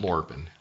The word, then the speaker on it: Lorpen: Okay, and then you have how do you pronounce that? – That's Lorpen? – Lorpen, yeah.